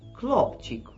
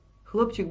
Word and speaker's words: Хлопчик: Но французы-музыканты не могли произносить «хэ», и у них получалось «клопчик», «клёп». Они не знали, что это значит, а певица была русской и знала, поэтому «хэ» она произносила жестко, как в слове, которое не печатали в Советском Союзе в книжках, но писали на заборах.